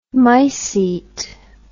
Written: mycete

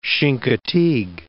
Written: Chincoteague Bay

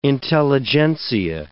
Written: intelligentsia

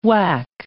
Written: wack

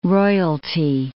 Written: royalty

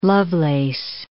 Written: Lovelace

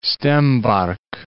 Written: Stebark

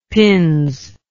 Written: pins

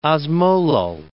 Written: osmolal

